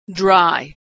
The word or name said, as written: dry